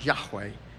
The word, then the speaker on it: Yahweh: By the way, anytime you see the word L-O-R-D in caps, you heard Brandon pronounce it that way. You'll hear me pronounce it. You'll hear most of the men pronounce it that way. It simply in the Hebrew means, or would it be pronounced Yahweh.